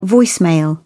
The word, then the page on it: voicemail: British English